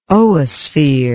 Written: oosphere

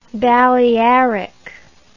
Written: balearic

